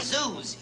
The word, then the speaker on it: Zeus: See, his last name is Zeus. You know, rhymes with booze.